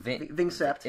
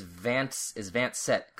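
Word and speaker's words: vingt-sept: Also, Vincept. – Is Vance Set, kind of. French is weird to pronounce, and I'm sure there was even more of a disconnect, because it's Japanese people trying to pronounce French.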